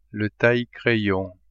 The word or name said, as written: taille-crayon